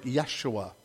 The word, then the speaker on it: Yeshua: In Hebrew, and my Hebrew pronunciation is like preschool level, so in Hebrew it sounds something like Yeshua is Joshua.